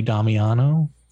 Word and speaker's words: Damiano: Is it Jerry Damiano? Did I say his name right? – That is correct, my friend.